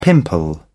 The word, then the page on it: pimple: British English